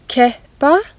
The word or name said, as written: keppa